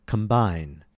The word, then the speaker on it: combine: We pronounce the B in combine because the B is coming in the middle of the word.